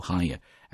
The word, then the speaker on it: hangar: 注意这个单词在读的时候那个 G 不发音，直接读 hangar at Mojave Air and Spaceport。